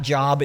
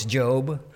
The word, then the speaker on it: Job: It's not Job, it's Job. Okay, it's spelled Job, but it's Job.